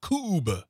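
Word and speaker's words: kubb: It is K-U-B-B, B as in boy, Coob. – Yep. Rhymes with tube.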